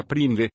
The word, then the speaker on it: PRNDL: El selector de marchas a menudo se denomina PRNDL, que se pronuncia PRINDL, independientemente de las letras o números que se utilicen.